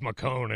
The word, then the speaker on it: McCone: And your name is McCone, and it rhymes with throne.